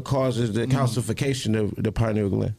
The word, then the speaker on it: pineal gland: It's the pineal gland.